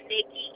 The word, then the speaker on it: Nikki: Is that V-I-C-K-Y? – It's Nikki, N-I-K-K-I. – I'm sorry. N-I-K-K-I? – Uh-huh.